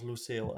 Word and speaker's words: Lusail: Ik vroeg aan in, in ons voorgesprekje van hoe spreek je dit nou uit? Nou, jij, jij zegt op zijn Engels. – Ja, ik spreek geen Arabisch hoor, maar ik hoor iedereen het als Lucille uitspreken.